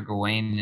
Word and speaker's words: Gawain: I can't remember which one it was, but it's got Sir Gawain in it. That's how you say his name.